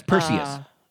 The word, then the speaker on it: Perseus: No, you know, I'm sure that Persis, who by the way is a Greek god, that's Perseus.